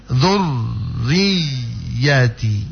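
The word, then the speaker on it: dzurriyyati (ذُرِّيَّتِي): Riyati, diyati itu dia cuma ditekan bu, cuma ditekan, jangan ditahan. Jangan baca dzurriyyati. Jangan begitu. Tapi cuma ditekan dzurriyyati, dzurriyyati, ya, gitu.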